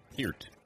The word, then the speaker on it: Peart: Peart. Paulie, make sure that I get that pronunciation right. Spelled Peart, but it's Peart of Rush.